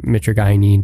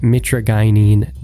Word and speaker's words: mitragynine: So first off, makes sense, but Kratom, taken as, you know, the dried leaf, it's less powerful than taking the primary alkaloid mitragynine by itself. That's another thing I learned. It's pronounced mitragynine, not, as I've been saying this whole time, mitragynine. So there's something. Mitragynine.